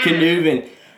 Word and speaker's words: Knueven: Knueven.